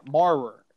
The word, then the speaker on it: Maurer: I don't know if it's – I'm just saying it sounds like one syllable. Yeah, Maurer. It's going to sound like one syllable when I pronounce it the rest of the show.